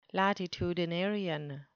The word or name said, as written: latitudinarian